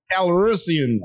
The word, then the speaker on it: Calrissian: Calrissian. – Lando Calrissian.